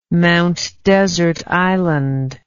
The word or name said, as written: Mount Desert Island